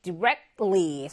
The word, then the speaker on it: directly: 그 다음에 두 번째, directly. 선생님이 여기 있는 T가 잘 발음이 안 된다라고 했어요. direct 하고, 한번 참았다, ly 이렇게 갔거든요. 그래서 directly 해서 직접적으로.